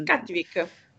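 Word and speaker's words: Cattivik: no, anch'io un bel po', anche eh, Cattivic si pronuncia così? Non mi ricordo come si pronuncia. – Sì, Cattivic.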